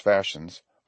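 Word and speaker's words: fascines: The fashions, pronounced fashions, are, as I said, bundles of brush bound snugly together, cut off straight at each end.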